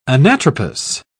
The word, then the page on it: anatropous: British English